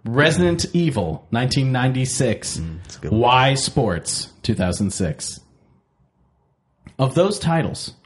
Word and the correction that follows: Wii Sports: What sports? What are you mispronouncing? – Wii Sports.